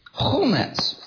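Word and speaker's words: chumetz (חמץ): The related word to chametz, and it's actually spelled exactly the same way, you know, the chet, mem, and sadi, is chumetz.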